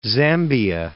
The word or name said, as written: Zambia